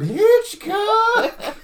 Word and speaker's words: Hitchcock: I'm Alfred Hitchcock.